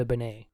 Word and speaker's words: Benet: I brought up, so the reason this is relevant is because Bill Bixby was married to actress Brenda Benet, who I erroneously said her name was Brenda Bennett. – Oh. – But there's only the one T. It is not pronounced Brenda Bennett.